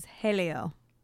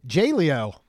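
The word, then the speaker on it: Jaleo: So I think I'm pronouncing this correct. It is Helio. – I can't remember what it is now. – Helio. – Because when I first, I was like, oh, J-Leo.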